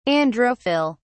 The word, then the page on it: androphyll: British English